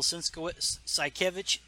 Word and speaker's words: Sienkiewicz: Bill Sienkiewicz, that's how you say it.